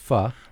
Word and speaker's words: pho: and it is pronounced pho, right? – It is. – Although spelled pho, Vietnamese rice noodles in a beef broth.